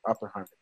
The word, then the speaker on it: Oppenheimer: Oh, dude, I want to see the new movie about Upper Farmer. I can't even pronounce his name. About the director that created the nuclear bomb. – Oppenheimer? – Oppenheimer, thank you.